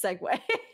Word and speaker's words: segue: All right, well, my partner has come in to ask, isn't it actually pronounced segue? – It is pronounced. That's what I was going to say. I was going to be like, isn't that actually how you pronounce the word?